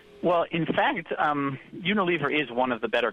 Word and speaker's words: Unilever: Yeah, Unilever.